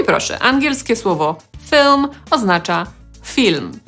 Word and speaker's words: film: No choćby po to, żeby zapamiętać, że ono ma trochę inną wymowę, no bo nie mamy w nim polskiej samogłoski i, tylko raczej y. Film.